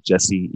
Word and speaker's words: Jesse: I'm mainly on Instagram, Facebook, and LinkedIn, but people can just type in my name on there, Jesse Iwuji. That's J-E-S-S-E. Last name is I-W-U-J-I.